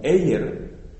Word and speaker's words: Ayer: Hay un filósofo inglés, entiendo que es él, que se escribe Ayer, pero se pronuncia como Ayer. Y este Ayer, o que nosotros escribiríamos o leeríamos en castellano Ayer,